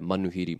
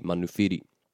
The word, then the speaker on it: manuhiri: It's kind of similar with the pēhea, pēwhia that you hear. Manuhiri, manuwhiri.